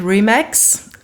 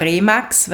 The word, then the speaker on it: RE/MAX: Wie spricht man es denn eigentlich aus? Ja, international heißt es natürlich RE/MAX aus dem Englischen. Wir in Österreich sagen eigentlich eher RE/MAX, weil es geläufiger ist.